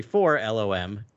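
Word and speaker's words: 4-LOM: So it's actually for LOM and Zuckus, because every time you see his name in this issue, it is phonetically spelled out for LOM.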